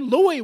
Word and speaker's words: Louis: St. Louis was the way. And by the way, the correct pronunciation is Louis, not Louis. Louis is L-E-W-I-S. L-O-U-I-S is Louis.